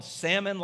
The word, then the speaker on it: Salmon: It was spelled salmon like the fish, but pronounced Salmon like it's spelled.